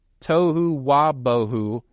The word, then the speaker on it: tohu wabohu: The Hebrew words, and I'm probably going to mispronounce this, but tohu wabohu, use the expression without form and void. Did I pronounce that right, Brother Nathan?